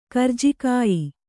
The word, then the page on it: ಕರ್ಜಿಕಾಯಿ: kannada